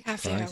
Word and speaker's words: Kavya: Kavya, is that how you say it? – Kavya Award, yeah.